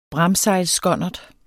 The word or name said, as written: bramsejlsskonnert